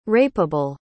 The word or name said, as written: rapeable